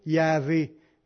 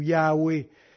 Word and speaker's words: Yahvé: mais la vraie prononciation, ça serait plutôt Yahvé ou Yahweh.